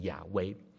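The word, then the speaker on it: Yahweh: 因为呢这个字原来在希伯来文的原文呢，如果要用发音来发的话呢，就是亚威，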